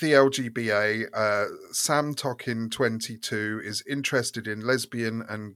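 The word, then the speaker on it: Samtökin: Yeah, Samtokin 22, yeah.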